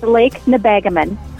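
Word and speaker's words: Lake Nebagamon: It's Lake Nebagaman.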